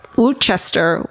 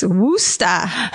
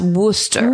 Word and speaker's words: Worcester: Also, turns out Woochester is nuts. It's Wooster. Did you know that? – I wouldn't have known it from spelling. – It's Worcester. – It's spelled Worcester. – Yeah. – And it's pronounced Wooster.